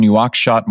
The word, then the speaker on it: Nouakchott: Nouakchott, Mauritania.